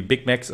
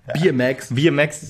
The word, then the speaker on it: Big Macs: genau, oder irgendwie Big Macs oder so. – Bier Max, Entschuldigung, hab falsch ausgesprochen.